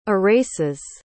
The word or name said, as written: erases